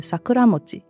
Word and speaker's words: Sakuramochi: 譬如在第 12集《初春的味道》讲到两种都叫做樱饼的核果子，因为中文发音相同，所以我们特别请主播在华语后面加上日语的发音 Uguisumochi 和 Sakuramochi，好让有声书的读者能区别两者的不同，理解上更加清楚。